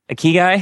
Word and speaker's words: ikigai: Is that ikigai? – Ikigai? – Ikigai. And so the Japanese word ikigai is this finding this joy in everything you do, but that's a oversimplification of what that means.